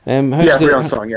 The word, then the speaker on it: Briançon: The team, forgive the pronunciation here, is it Briançon? – Briançon, yeah.